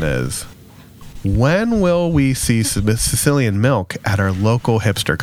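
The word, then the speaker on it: caecilian: So a group of scientists from Brazil that were studying a worm-like amphibian called the Sicilian, and I promise you, I looked up that pronunciation. It is Sicilian. I am not the one that is making that link phonetically or culturally. – It's a C-A-E. – It is C-A-E, but it is pronounced Sicilian.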